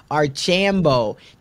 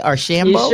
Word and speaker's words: Archambeau: I'm excited to learn from the one and only Shellye Archambeau. Did I say that right? Archambeau? – You sure did.